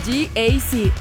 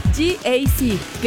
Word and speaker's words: GAC: Se escribe GAC, pero se pronuncia GAC. Repítelo, GAC.